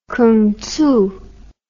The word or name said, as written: kungtzu